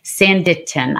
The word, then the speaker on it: Sanditon: Sanditon. I never say that right. Sanditon. See, I told you I don't pronounce Sanditon, an unfinished Jane Austen novel.